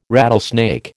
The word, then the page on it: rattlesnake: American English